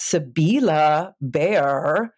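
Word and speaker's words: Sibylle Baier: So, I realize I've been pronouncing her name wrong all this time. It's pronounced Sybilla Bear.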